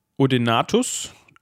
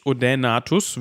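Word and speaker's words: Odaenathus: Ähm, und der Odenatus, Odenatus würde ich es aussprechen, wegen dem AE,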